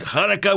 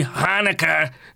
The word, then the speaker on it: Hanukkah: I pronounce it Hanukkah with a H, not the easy Hanukkah that's put out there for non-Jews to pronounce.